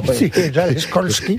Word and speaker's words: Skolsky: No, señor, no es SH, es SKOLSKY.